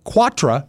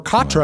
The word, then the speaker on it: quatre: It's actually a mispronunciation of the French word quatre, or quatre, right, meaning four. Quatre corner.